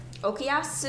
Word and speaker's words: Okuyasu: Okuyasu. – You can Okuyasu.